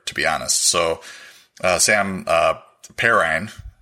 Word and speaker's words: Perine: So this is pronounced pairing. – Perine. Yep. – those of you listening to me know I can't pronounce anybody's name. So Perine.